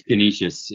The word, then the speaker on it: Canisius: Canisius? – Yes. Yes. – Pronounce that right. – It's Canisius in upstate New York.